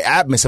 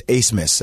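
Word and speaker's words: Abmas: Like, these are the teams, like, when you find out who Max Asmus is, his name is actually spelled A-B-M-A-S. I've had so many people, hey, this guy, Atmus. I'm like, actually, it's Asmus. That's how you pronounce his name.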